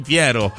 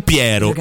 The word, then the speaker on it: Piero: Dovresti dire Piero. Però vabbè. – Posso o posso? – No, posso. Ti faccio sentire, per esempio. – Corpo o corpo? – Corpo. Però ti faccio sentire qual è la pronuncia esatta di quei due nomi. – Botte o botte? – Luminoso pomeriggio d'aprile. E i due giovani Giorgio e Piero. – Capito? Giorgio e Piero.